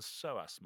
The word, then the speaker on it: psoas: And there's something called the psoas muscle. It's spelled P-S-O-A-S.